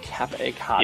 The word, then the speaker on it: Capicotto: Oh, Mark Capicotto. – Capricotto, okay. – Capicotto. – Capicotto, yeah. Okay.